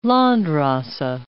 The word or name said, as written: Landrace